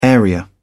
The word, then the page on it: area: British English